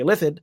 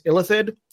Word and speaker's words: Illithid: Illithid. It's just an emphasis on a different syllable.